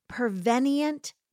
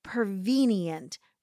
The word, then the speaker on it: prevenient: This is a theological principle known as prevenient grace or prevenient grace. I've heard it pronounced both ways.